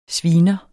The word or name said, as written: sviner